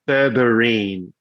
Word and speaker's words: berberine: Is it spelled the same way as the clothing label, B-U-R-B-E-R-R-Y? – It's berberine. So B-E-R-B-E-R-I-N-E. – Berberine. Okay. So kind of like Wolverine, but berberine kind of thing.